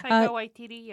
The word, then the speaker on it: Waititi: Am I saying it right? – Waititi, yeah.